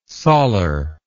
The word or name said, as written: sollar